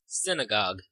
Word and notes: synagogue: American English

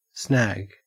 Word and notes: snag: Australian English

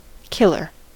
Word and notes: killer: American English

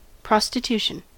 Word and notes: prostitution: American English

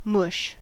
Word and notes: mush: British English; American English

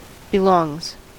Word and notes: belongs: American English

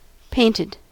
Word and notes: painted: American English

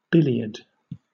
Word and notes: billiard: British English

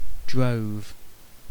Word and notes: drove: British English

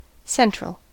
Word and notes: central: American English

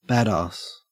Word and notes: badass: Australian English

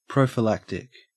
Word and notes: prophylactic: Australian English

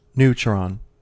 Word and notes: neutron: American English

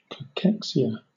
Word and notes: cachexia: British English

